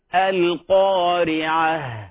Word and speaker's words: القارعة: والصحيح أن التاء المربوطة تاء التأنيث المربوطة إذا وقف عليها تنطق بهاء ساكنة القارعة الحاقة وهكذا.